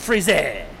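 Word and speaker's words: Frisé: I am Le Bichon Frise. – Ah, yes, I have heard of you. The small fluffy dog with the lethal bite. It is pronounced freeze, though, no? – It is frise.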